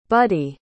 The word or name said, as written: buddie